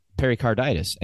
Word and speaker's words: pericarditis: Lo and behold, we come to find out after certain scans and tests, we find out he has pericarditis.